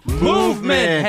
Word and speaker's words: MVMT: Movement! – Hey, what time is it? – MVMT time, baby. – MVMT time, baby. It's pronounced movement.